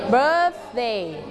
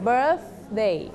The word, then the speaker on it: birthday: birthday don't forget to pronounce this sound birthday